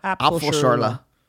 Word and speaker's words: Apfelschorle: Apfelschorle.